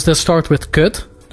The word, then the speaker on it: kut: Wait, so how do you say it again? – Kut. – Kurt. Like that? – Kut. – Kut. – It's spelled K-U-T.